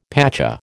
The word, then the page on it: pacha: American English